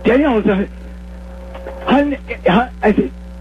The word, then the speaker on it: Hyundai: So it was Sunday as Hyundai as in Sunday,